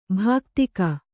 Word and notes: ಭಾಕ್ತಿಕ: bhāktika

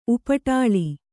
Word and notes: ಉಪಟಲ: upaṭala

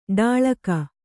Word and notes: ಢಾಳಕ: Kannada

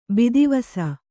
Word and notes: ಬೂದಿವೂಸು: būdivūsu

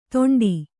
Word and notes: ತೊಂಡಿ: Kannada

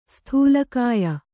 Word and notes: ಸ್ಥೂಲಕಾಯ: sthūla kāya

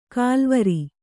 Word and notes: ಕಾಲ್ವರಿ: kālvari

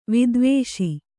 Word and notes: ವಿದ್ವೇಷಿ: Kannada

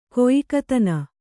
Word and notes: ಗಾಯಕತನ: gāyakatana

